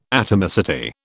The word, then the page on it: atomicity: British English